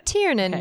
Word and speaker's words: Tiernan: Well, her last name is spelled T-I-E-R-N-A-N. – Tiernan. – Okay. Tiernan.